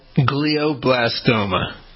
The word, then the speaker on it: glioblastoma: Glioblastoma. – All right. So what's the story? – The glioblastoma, Doug, this is an aggressive type of cancer that can occur in the brain or spinal cord. Glioblastoma, it forms in cells that are called astrocytes that support nerve cells.